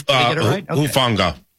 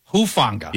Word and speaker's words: Hufanga: Talanoa Hufanga. Did I say that right? You're good with pronunciations. – Hufanga. – Hufanga. Yeah. Thank you.